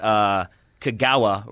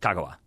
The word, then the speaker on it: Kagawa: Because we would always get Kagawa wrong because we would hit the ga, and instead it's like Kagawa.